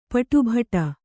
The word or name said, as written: ಪಾಟುಬಡು